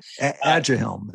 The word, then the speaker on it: Aduhelm: And I kind of want to talk about Adalhem or Aducanumab. I hope I'm saying that right. – Aduhelm. It's easier that way.